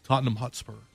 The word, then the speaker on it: Tottenham: How do you pronounce that? Really, Tottenham Hotspur. – Tottenham Hotspur. – Okay. – Tottenham. Not Tottenham. – Tottenham. – I thought over here it'd be Tottenham.